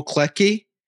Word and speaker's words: Klettke: I'm excited to welcome Joel Klettke.